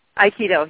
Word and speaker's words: Aikido: Aikido, yes. – Aikido, okay. You studied that as an undergrad in 75, and for those listeners out there that may not know what Aikido is, can you explain that? – Well, Aikido is a Japanese martial art.